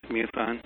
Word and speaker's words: MUFON: Or you call it MUFON, is it, how do you pronounce it? – Yes, MUFON.